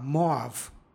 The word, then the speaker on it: Moav: stiha, pročitali smo Moav. Ovdje bi slovo as, posle vokala, trebalo da ima izgovornu vrednost ja. Međutim, u srpskom jeziku ovdje izgovaramo a, Moav.